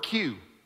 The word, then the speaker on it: queue: All right, take this word, queue. You know, when you go to Six Flags and you get in the queue line. That is the letter Q with four silent letters after it.